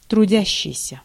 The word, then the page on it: трудящийся: /trʊˈdʲæɕːɪjsʲə/